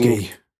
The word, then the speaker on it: Jodorowsky: Jodorowsky.